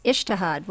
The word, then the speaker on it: ijtihad: You describe this as, I think the word is ijtihad. Am I pronouncing that correctly? – Yes, ijtihad,